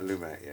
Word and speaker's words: Lumet: I think it's Lumet. – Lumet, yeah.